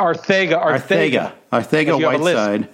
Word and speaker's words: Arcega-Whiteside: I took Arcega. I can't even pronounce it. – Arcega-Whiteside. Yeah, like as if you had a lisp.